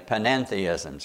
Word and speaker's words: panentheism: And finally, panentheism's got an extra syllable there. It's almost a twin to pantheism with a slight difference.